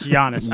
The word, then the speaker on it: Giannis: It's Giannis.